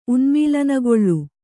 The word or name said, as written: ಉನ್ಮೀಲನಗೊಳ್ಳು